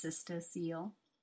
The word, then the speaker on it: cystocele: I just learned how to pronounce that word all these years. I've been saying it in my mind as cystocele. It's cystocele, C-Y-S-T-O-C-E-L-E.